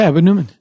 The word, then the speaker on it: Edwin Newman: And Edwin Newman, I hope I'm saying his name right. – Yeah, Edwin Newman.